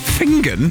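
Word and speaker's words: Finzean: Please spell Finzian. Pronounced Fingen. – Fingen? – Fingen.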